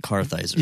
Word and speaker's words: Kartheiser: It's Vincent Kartheiser.